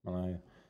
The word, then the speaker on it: Malachi: How do you say that? Malachi.